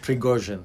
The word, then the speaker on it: Prigozhin: Prigozhin.